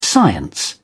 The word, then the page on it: science: British English